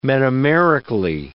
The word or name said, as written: metamerically